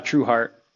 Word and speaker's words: Trueheart: True heart, like just true heart.